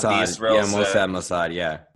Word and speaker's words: Mossad: Because some people believe that Mossad, is that how you pronounce it? Mossad. – What? – Mossad. – Mossad, yeah.